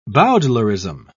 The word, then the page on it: bowdlerism: British English